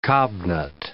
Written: cobnut

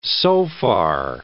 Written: sofar

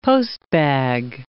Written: postbag

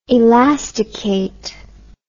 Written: elastication

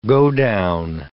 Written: godown

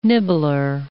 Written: nibbler

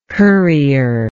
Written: currier

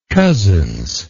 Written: cozzens

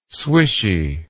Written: swishy

